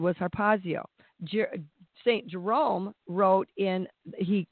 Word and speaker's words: harpazo: He wrote in Greek, and the word he used was harpazio, and it's spelled H-A-R-P-A-Z-O, harpazio, and it means to seize, carry off by force, to seize on.